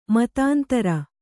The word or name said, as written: ಮತಾಂತರ